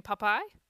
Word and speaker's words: Popeye: And something about, it's just two syllables, but it's the funniest thing to me that she emphasizes the second syllable. She's always done it. Oh, Popeye. Popeye. – Popeye. – Obviously. – Like papaya, but Popeye. Oh, that's so cute.